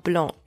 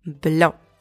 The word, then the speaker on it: blanc: A quick tip, because I hear this often in English-speaking country, do not pronounce the C at the end of blanc. Le vin blanc, not le vin blanc. Le vin blanc.